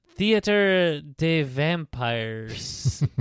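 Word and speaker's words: Théâtre des Vampires: How do you say theater? How would you say that in a French accent? – Theater de vampires. – Yeah. That's what it is.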